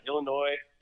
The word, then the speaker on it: Illinois: Illinois. I think the S is silent.